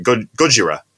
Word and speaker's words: Gojira: For the correct pronunciation. Gojira. So yeah, me and Ryan did a lot of research this week.